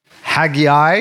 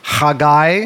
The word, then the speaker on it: Haggai: We are going to be going over the book of Haggai, you might pronounce it, or Haggai is how you say that in Hebrew.